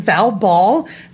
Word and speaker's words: Val Ball: My name is Val Ball, rhymes with foul ball,